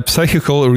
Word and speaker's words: psychical: De director van de Psychical Research Foundation... – Psychical? The P is silent.